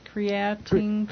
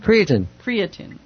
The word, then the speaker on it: creatine: So creatinine is that how you pronounce it? – Creatine. – Creatine. Okay.